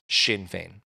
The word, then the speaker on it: Sinn Féin: 2.5 years later, the separatist Sinn Féin Party won, which is really confusing spelling, S-I-N-N-F-E-I-N. And it's pronounced Sinn Féin.